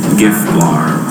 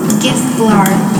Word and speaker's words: gifblaar: Gif blar. – Gif blar. G-I-F-B-L-A-A-R. Gif blar.